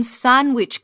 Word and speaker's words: sanguich: And if you're Miamian, this is how you say sandwich. Sandwich. – Sandwich. – Okay? Sandwich. S-A-N-G-U-I-C-H.